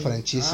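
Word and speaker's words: Franzese: Michael ¿Cómo se dice? Me olvida. – Michael Francisi, Francisi, Francesi. – Michael Francesi.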